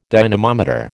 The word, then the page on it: dynamometer: American English